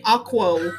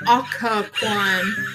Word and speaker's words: Occoquan: Because you're saying aqua. It's Occoquan. No, not aqua.